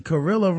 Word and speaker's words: Carrilla: Carrilla rhymes with gorilla.